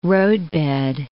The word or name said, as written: roadbed